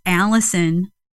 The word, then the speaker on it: Allison: My email there is Allison, A-L-L-I-S-O-N, just spelled like all is on.